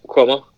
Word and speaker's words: Cuomo: And especially, especially in New York, is it Cuomo? Did I say it right? – Cuomo. – Cuomo.